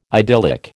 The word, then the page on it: idyllic: American English